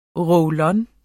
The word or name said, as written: roll-on